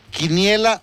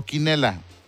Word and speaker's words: quiniela: Sí, porque queremos saber, como tú eres una persona muy culta, muy estudiada, ¿cómo se dice? ¿Quiniela o quinela? – Se dice quiniela.